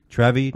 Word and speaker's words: Trevi: Is it, I don't know how it's pronounced, Trevi, T-R-E-V-I, Trevi. Trevi, Dougie.